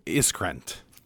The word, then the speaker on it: Iskrant: I'm Communications Director here at NCQA, and today we're joined by Katharine Iskrant.